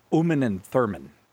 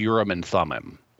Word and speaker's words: Urim and Thummim: So we got the Cuban Missile Crisis, then we got Close Encounters, and then the mysterious, a lot of times I hear Americans say Uman and Thurman. – No, that would be wrong. What you will hear Americans sometimes say is Urim and Thummim. And that's a more Americanized pronunciation, which is fine.